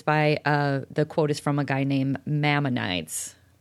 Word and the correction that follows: Maimonides: It's M-A-I-M-O-N-I-D-E-S. – close enough. – Maimonides? Maimonides probably is better. M-A-I-M, it sounds like MAME.